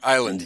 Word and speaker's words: island: Or island, yeah. The S is silent in English here, island. An island which is called Odin's Island in Finn, F-Y-N.